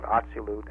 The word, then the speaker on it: Atziluth: In the Kabbalistic terminology, that would be called Atzilut, and it's spelled Atziluth.